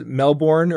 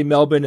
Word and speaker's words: Melbourne: What's funny is everybody outside of Australia says Melbourne, or you know, but the locals say Melbourne, and that's what they want us to say, and that's what we will say.